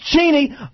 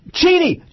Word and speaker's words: Cheney: Don't forget, folks, it's pronounced Cheney. Cheney. Cheney.